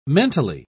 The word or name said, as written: mentally